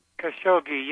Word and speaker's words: Khashoggi: Khashoggi, yeah.